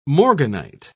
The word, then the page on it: morganite: British English